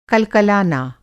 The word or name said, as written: कलकलाना